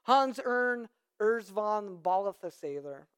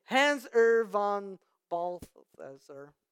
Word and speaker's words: Hans Urs von Balthasar: Hans Urs von Balthasar.